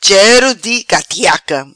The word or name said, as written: Chero di catiaca